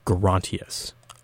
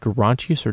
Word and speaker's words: Gerontius: We did have some debate, I remember this, about whether it's pronounced Gerontius or Gerontius. There is historical merit for both, but I want to say the evidence pointed us slightly towards Gerontius.